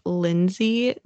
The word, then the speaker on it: Linzi: Granted, her name is Lindsay, but it's spelled L-I-N-Z-I.